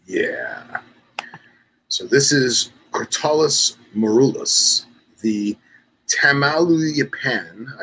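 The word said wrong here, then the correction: Tamaulipan: I always say that wrong. Tamaluyapan rock rattlesnake. – Tamulipin. – Tamulipin.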